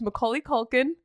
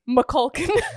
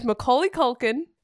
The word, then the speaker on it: Macaulay Culkin: The Good Son stars Macaulay Culkin. You know how hard it was for me to say that correctly? – Yep. – I usually say Macaulkin. – So it stars Macaulay Culkin, Elijah Wood, and Wendy Crewson.